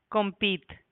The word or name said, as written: compit